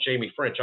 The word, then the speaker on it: Ffrench: And, man, Ffrench is by the way, it is pronounced Jamie Ffrench.